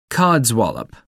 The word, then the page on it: codswallop: American English